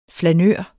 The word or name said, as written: flanør